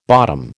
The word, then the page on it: bottom: American English